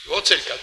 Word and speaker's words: Wojtyla: Professor Wojtyla? Sì, pronuncio bene, ma non credo. – Sì, Wojtyla, giusto.